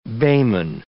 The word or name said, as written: bayman